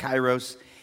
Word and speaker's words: kairos: This is a word called, in the Greek, called kairos, pronounced kairos, and it means God's timing, not chronos, which is like chronological time, but the moment of God breaking in.